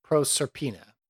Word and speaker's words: Proserpina: So that's spelled P-R-O-S-E-R-P-I-N-A. Proserpina. Proserpina? Proserpina.